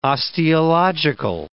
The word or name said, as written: osteological